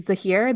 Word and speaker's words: Zahir: Is it Zahir, the guy with the poison? – That's right.